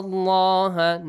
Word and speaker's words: Allah: Allah is pronounced heavily.